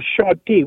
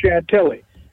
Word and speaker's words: Chantilly: a place called Chanty, which the Americans. – I knew you were going to say that. I knew where you were going with this. Go ahead. – What it's pronounced Chantilly, and of course they're famous for their Chantilly lace.